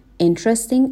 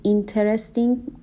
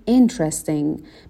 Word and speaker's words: interesting: Interesting, استرس بخش اول کلمه هست. نگیم interesting، به این صورت تلفظ نکنیم، بگیم interesting.